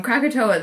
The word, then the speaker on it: Krakatoa: The next book on my list is, excuse me if I pronounce this wrong, Krakatoa. – Yeah, that's excellent. Krakatoa, The Day the World Exploded by Simon Winchester.